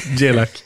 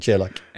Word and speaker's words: Grzelak: Med reservation för uttalet, för det var fel. – Jelak. – Ja, Jelak.